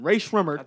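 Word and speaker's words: Rae Sremmurd: Ray Shremmerd.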